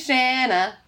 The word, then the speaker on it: Shanna: My name is Shanna. It rhymes with banana.